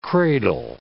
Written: cradle

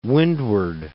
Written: Windward Islands

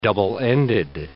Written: double-ended

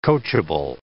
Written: coachable

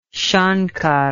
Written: shankar

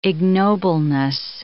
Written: ignobleness

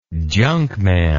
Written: junkman

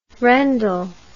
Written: rendell

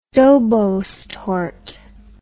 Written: dobos torte

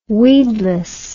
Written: weedless

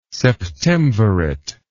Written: septemvirate

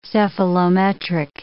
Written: cephalometric

